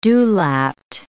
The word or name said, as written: dewlapped